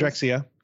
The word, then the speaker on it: Drexciya: That the, the Reynolds flags, and I think I'm saying this right, Drexciya? Drexciya? Any guesses? Yeah, Drexciya. – Drexciya. Drexciya. Okay, good, good.